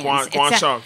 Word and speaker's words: Guangsha: The Zhejiang Gangsha Lions. – Guangsha. Yeah.